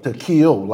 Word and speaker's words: Tekeel: You did say it right, Tekeel. – It's Tekeel, like an E.